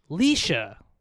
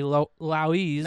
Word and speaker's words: Laoise: Well, it's actually pronounced Leisha. – No, it's not. Like Laos? Like L-A-O-I-S-E. – L-A-O-I-S-E, Louise. – Now, if this was Hawaiian, how would you pronounce it? – It was Laise would be her name. But it's pronounced Leisha. Like a mix between like a girl named Lisa that you put on a leash.